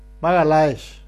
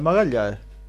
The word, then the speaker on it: Magalhães: No Magalhães, no es Magalhães. – Magalhães, la LH se pronuncia como la L.